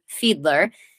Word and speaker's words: Fiedler: You do actually pronounce my name Fiedler.